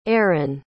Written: erron

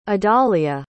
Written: adalia

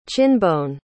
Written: chinbone

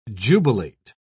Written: jubilate

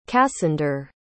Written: cassander

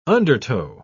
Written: undertow

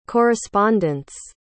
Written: correspondents

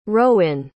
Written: rowin

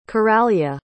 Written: coralia

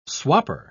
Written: swapper

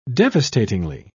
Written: devastatingly

